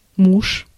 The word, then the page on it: husband: /ˈhʌz.bənd/